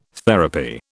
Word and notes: therapy: British English

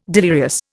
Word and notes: delirious: Indian

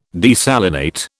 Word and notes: desalinate: British English

